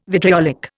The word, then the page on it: vitriolic: Indian